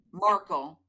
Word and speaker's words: Markle: that I saw so much Meghan Merkel. Am I even saying it right? And is that her name? Meghan Markle.